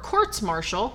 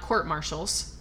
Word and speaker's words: courts-martial: Unfortunately, his army career didn't last long because he had four courts-martial, which I found out is the correct way to say it, not court-martials.